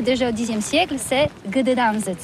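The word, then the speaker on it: Gdańsk: Ça s'écrit G-D-A-N-S-K. On dit Gdansk ou Dansk, on prononce le G ou pas?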